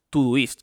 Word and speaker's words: Todoist: O si no, por ejemplo, yo uso Todoist, que se escribe todo ist, como todo list o Todo List, pero sin la L.